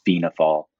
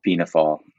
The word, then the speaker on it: Fianna Fáil: Um, there are a couple pronunciations of the political party. Um, the the easiest way for non Irish speakers is Fianna Fáil. – Fianna uh, Fáil. Uh, Fianna Fáil.